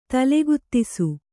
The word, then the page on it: ತಲೆಗುತ್ತಿಸು: kannada